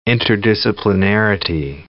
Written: interdisciplinarity

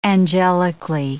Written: angelically

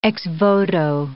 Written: ex-voto

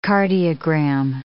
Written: cardiogram

